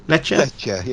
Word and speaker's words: Lecce: Is it Lecce? – Lecce, yeah. Okay.